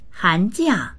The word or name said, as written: han2jia4